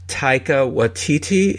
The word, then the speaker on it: Taika Waititi: There's filmmakers like David Filoni and Taika Waititi.